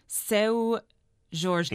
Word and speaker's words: Seu Jorge: And of course, the supporting actor is a very well-known Brazilian actor and musician, and I don't want to butcher the name, but it's pronounced Seu Jorge, and he is very well known in this country for being in The Life Aquatic with Steve Zissou.